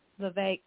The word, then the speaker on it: Vivek: Vivek, yes.